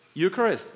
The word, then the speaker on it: Eucharist: Eucharist. Oh, that's pretty good. Okay, Eucharist, which in Spanish is easy for me because it's the same word in Greek, Eucharistia, right? And when I read the word Eucharistia, I was like, no, I'm out of this.